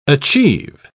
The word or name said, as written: achieve